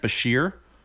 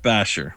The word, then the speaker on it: Bashir: B-A-S-H-I-R. – Is that Bashir? – Not Bashir. – Pronounced Basher.